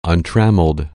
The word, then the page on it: untrammeled: anglais